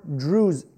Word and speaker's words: Druze: And the article writes that among the new Christians were former Sunni Muslims and Shia Muslims, former Yazidis, and former Druze, I think is how you pronounce it, D-R-U-Z-E.